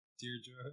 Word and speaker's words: Deirdre: Is it Deirdre? Deirdre? – Deirdre.